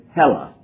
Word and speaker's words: Hellas: And I'm pronouncing it wrong, but it's Hellas, H-E-L-L-A-S.